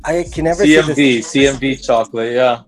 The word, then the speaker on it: Txokolat: So it's spelt in the Basque way of spelling chocolate, Spanish Basque. – And then you can, but you still pronounce it chocolate.